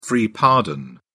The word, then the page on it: free pardon: British English